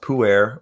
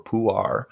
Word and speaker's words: Pu'er: Well, yeah, and people call it like it's either pronounced Pu'er or Pu'ar.